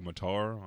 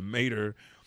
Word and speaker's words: Mater: I was like, Matar? I'm sitting here thinking anime reading. Mater rhymes with tater on a cross-country road trip.